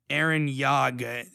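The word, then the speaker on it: Yagh: This guy, at Aaron Yag. It's a silent H at the end. – Oh, Aaron. – It's a Y-A-G-H.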